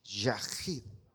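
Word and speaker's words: yahid (יחיד): A ver, repitan ahora, yahid. Pero así como, que le salga así como, esa pronunciación así como.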